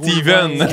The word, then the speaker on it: Stephen: C'est comme Stephen Harper. On dit Stephen, mais ça s'écrit Stephen.